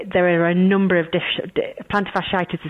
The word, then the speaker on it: plantar fasciitis: Because one of the ones that we had in our top 10 was a thing called plantar fasciitis.